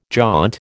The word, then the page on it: jaunt: American English